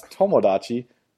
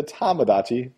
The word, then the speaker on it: Tomodachi: A lot of people mispronounce Tomodachi to Tamagotchi because of Tamagotchis, though.